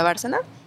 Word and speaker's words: Bárcena: que es Alicia Bárcena. Sí, ¿verdad? Sí, lo dije bien. – Sí, es correcto. – Es correcto, Coco.